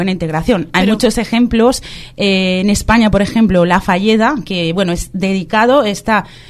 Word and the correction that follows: Fageda: digamos, La Fagueda. – Sí, bueno, pues con bueno, decimos en castellano, Fageda, se escribe, ¿no? Con G, La Fagueda, en Gerona, que es como una empresa que han montado exclusivamente para personas con problemas de salud mental y que funciona perfectamente.